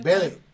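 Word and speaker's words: Vélez: Velez, sí, el acento. Velez. El acento en la primera E.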